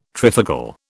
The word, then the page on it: trifocal: British English